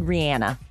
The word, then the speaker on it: Rihanna: A reporter flat out asked her on the red carpet one time, because we were confused, how do you pronounce her name? She said Rihanna.